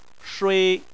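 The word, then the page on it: shui: tone 1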